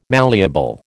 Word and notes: malleable: American English

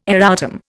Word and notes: erratum: Indian

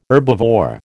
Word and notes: herbivore: American English

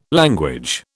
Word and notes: language: British English

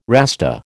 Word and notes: Rasta: American English

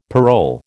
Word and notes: parole: American English

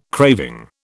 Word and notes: craving: British English

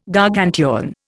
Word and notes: Gargantuan: Indian

